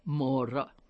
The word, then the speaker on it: mora: When you get there, you do what the locals say, mora, mora, slowly, slowly, Maura, Maura, slow down.